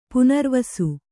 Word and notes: ಪುನರ್ವಸು: punarvasu

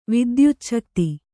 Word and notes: ವಿದ್ಯುಚ್ಛಕ್ತಿ: vidyucchakti

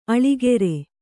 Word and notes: ಆಳಿಕಾರ: āḷikāra

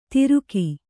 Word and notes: ತಿರುಕಿ: Kannada

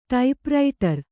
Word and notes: ಟೈಪ್‍ರೈಟರು: Kannada

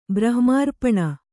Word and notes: ಬ್ರಹ್ಮಾರ್ಪಣ: brahma'rpaṇa

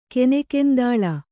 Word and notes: ಕನೆಕೆಂದಾಳ: kanekendāḷa; Kannada

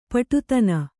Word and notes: ಪಡಿದನಿ: paḍi dani

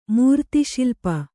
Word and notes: ಮೂರ್ತಿಶಿಲ್ಪ: mūrtiśilpa

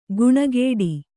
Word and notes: ಗುಣಗೇಡಿ: guṇagēḍi

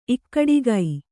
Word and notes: ಇಕ್ಕಡಿಕೆ: kannada

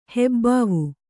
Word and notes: ಹೆಬ್ಬಾವು: hebbāvu

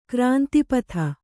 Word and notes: ಗ್ರಂಥಪಾತ: grantha pāta

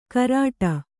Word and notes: ಕರಾಟ: karāṭa

